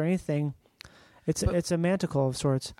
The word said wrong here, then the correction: manacle: You mean a manacle? – Yeah, a manacle. – I thought you said manticle. – I think I did. I think I said it wrong.